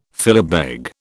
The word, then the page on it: philabeg: British English